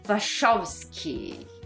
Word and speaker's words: Wachowski: Que são as irmãs Wachowski. É assim que se, se pronuncia Macari.